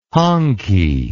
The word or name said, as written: honky